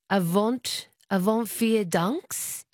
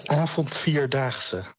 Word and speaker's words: Avond Vier Daagse: You've been doing the Avond Vier Daags. Is that how you pronounce the festival? – The Avond Vier Daagse. – Avond Vier Daagse. – Yes.